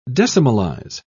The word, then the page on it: decimalize: British English